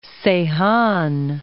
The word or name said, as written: Seyhan